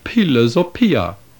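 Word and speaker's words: philosophia (φιλοσοφία): Here we have simple and clear proof that the word philosophy was pronounced in ancient Greek, philosophia.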